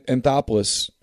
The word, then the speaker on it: Anthopoulos: And then Anthopoulos, am I saying his name correctly? – Yeah, that's right.